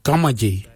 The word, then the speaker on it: gamadji: Jittery is spelled D-J-E-R-D-A-R-I. Jittery, brown, jittery. And the last colour for green, we say gamaji. Gamaji for green. Gamaji is spelled G-A-M-A-D-J-I.